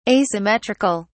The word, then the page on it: asymmetric(al): British English